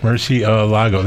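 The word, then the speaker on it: Murciélago: No, I think it's like Murcielago.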